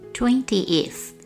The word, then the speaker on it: twentieth: 例如，二十是 twenty，t w e n t y 有两个音节，而第二十则是 twentieth，t w e n t i。 e t h twentieth 比 twenty 多出一个音节，这细微之处常常因为发音迅速念过而造成学生的盲点。